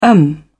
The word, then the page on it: em: British English